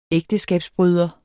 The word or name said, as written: ægteskabsbryder